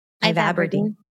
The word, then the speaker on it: ivabradine: Ivabradine.